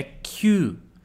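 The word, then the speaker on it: kyu (きゅう): Toma muito cuidado para não sair kyu. Tudo bem? É kyu. Kyu. Uma sílaba só.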